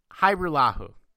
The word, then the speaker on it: Hajrullahu: The J is silent. Uh, it's Hyrulahu.